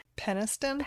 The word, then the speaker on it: Peniston: Peniston.